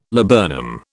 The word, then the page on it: laburnum: British English